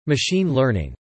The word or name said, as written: Machine learning